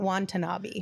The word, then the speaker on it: Watanabe: It's Watanabe, not Watanabe, right? – That's on him.